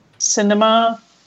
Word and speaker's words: Sinema: You know that Kirsten Sinema, is that how you say it? – I thought that's how I've heard it. – Cinema, like the movie theater.